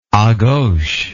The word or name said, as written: agauche